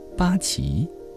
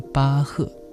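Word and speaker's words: Bach: 姑娘瞥了一眼他合起来放在腿上的乐谱，接着问道，巴奇、波奇这个词怎么念来着？菲利普尽量摆出慈祥长辈的风范说，实际上这个词念巴赫，他是从前一位非常有名的作曲家。巴赫，我听说过这个人。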